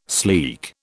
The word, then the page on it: sleek: British English